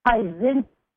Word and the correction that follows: Pieczenik: P-I-E-C-Z-E-N-I-K. Is this information accurate? – It's Pizinek. Pizinek. – Oh, Pizinek. Okay.